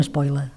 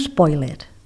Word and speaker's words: spoiler: Tothom diu un spoiler. I no. La manera en com s'ha de adaptar aquest manlleu a la llengua catalana és, en aquest cas, la pronúncia, i la pronúncia seria spoiler.